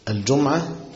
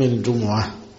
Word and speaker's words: الجمعة: الجمعة بتتنطق بضم الميم وتسكين الميم، الجمعة والجمعة، إذا نودي للصلاة من يوم الجمعة.